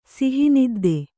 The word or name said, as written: ಸಿಹಿನಿದ್ದೆ